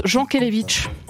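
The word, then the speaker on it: Jankélévitch: Jean Kelevitch ? – J'ai pas compris la question. – Oui, moi j'ai compris. J'ai dit Yankelevitch pendant toute l'émission et vous dites Jean Kelevitch.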